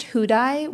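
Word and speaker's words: Houdaille: Housedale? Housedale? – It's pronounced Hudai, which Hudai. Is not how it's spelled.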